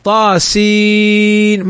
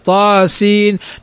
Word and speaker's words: طاسين: مثل طاء طاسين، ما نقول طاسي، لا، طاسين، نمدها بمقدار حركتين ما الطبيعي.